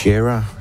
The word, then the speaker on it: Shara: Shara, som det i virkeligheden udtales. Altså Shara Nielsen, jeg sagde jo Sarah. Det er fordi, jeg lige sniger sig et H ind.